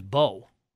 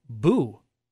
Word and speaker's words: Beau: My real name's Bo, B-E-A-U. And I had my name's been pronounced wrong my entire life. I had a substitute teacher one time, Nelly, who called me Boo, and it was health class.